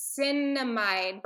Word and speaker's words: Niacinamide: Yeah, it's the Niacinamide brightening eye cream. Oh. Sorry. I'm like, I want to make sure I'm pronouncing it right. – You are. That's exactly right. You said it right.